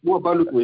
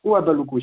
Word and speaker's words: Ouabalakou: Ouabalakou, hein. Je viens bien qu'on écorche mon nom, mais c'est Ouabalakou. – Pardon, excusez-moi. – C'est Ouabalakou, comme Ouagadougou. – Désolé, on n'est pas Ouabalakou, effectivement. Ouabalakou, effectivement.